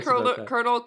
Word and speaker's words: Colonel: I love saying the word Colonel. Colonel. – Colonel. – That's how it's supposed to be.